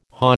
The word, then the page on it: hah: American English